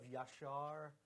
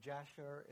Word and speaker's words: Yashar: There's Joshua 10, verses 12 and 13 that mentions the book of Yashar, or Jasher, if you want to mispronounce it.